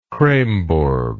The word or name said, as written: kreymborg